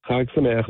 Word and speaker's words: Chag Sameach: Is it Chag Sameach? – Chag Sameach. Yeah, that means happy holiday. – All right. How'd my pronunciation? It's pretty good, right? – Great. Yeah. You got it.